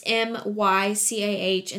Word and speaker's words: Mycah: But if you don't know, and you want to follow me on Facebook, my the way they spell my name because a lot of people misspell it for some reason, but it's M Y C A H and then Carpenter.